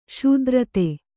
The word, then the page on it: ಸ್ಥಿರತೆ: sthirate